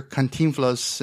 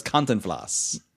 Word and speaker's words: Cantinflas: I always thought it was Cantinflas. – No, you're putting the wrong syllable. You're putting the wrong accent on the I.